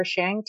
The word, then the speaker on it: Tsiang: May Shang, Sarah Shang, T-S-I-A-N-G.